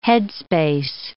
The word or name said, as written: headspace